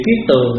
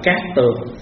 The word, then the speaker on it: cát tường: Chữ kiết tường á, có khi đọc là cát tường.